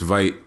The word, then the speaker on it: Veidt: i forget how you pronounce his last name but oh you know, Veidt? Blonde. i think it's Veidt.